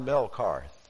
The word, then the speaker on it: Melkarth: It's spelled M-E-L-K-A-R-T-H, Melkarth.